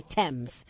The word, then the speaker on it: Thames: It's the silent H in Thames.